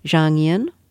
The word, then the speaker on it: Zhang Yin: And you write in your book about, tell me if I'm pronouncing her name correctly, Zhang Yin? – Yes. Yeah.